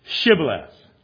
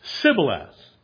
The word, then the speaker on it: Shibboleth: If he said, nay, I deny being an Ephraimite, then they put him to the test and said, say now, Shibboleth. And he said, Sibboleth. For he could not frame to pronounce it right.